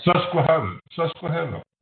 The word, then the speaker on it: Susquehanna: Susquehanna Growth Equity? I don't know. I probably butchered that name. But Susquehanna. That's the river. – Susquehanna? Okay, that's better.